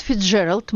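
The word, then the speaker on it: Fitzgerald: Фрэнсис Скотт Фицджеральд, мы решили произносить это имя так, как его произносят в Америке, родился 24 сентября 1896 года в семье обедневшего бизнесмена.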